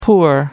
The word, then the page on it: poor: American English